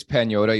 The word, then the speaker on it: Pagnotta: So his last name is Pagnotta. You don't pronounce the G in Italian.